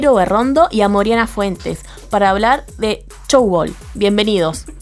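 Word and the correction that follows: Tchoukball: ¿Cómo se pronuncia? – Chukball. – Chukball. – Exacto.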